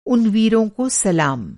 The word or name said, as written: सलाम